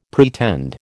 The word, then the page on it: pretend: American English